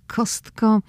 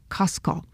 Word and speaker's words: Costco: I dzisiaj będziemy rozmawiali na temat Kostko.